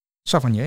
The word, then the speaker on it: Savanier: Savanier, zeg ik dat goed? Savanier, ja.